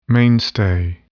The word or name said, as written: mainstay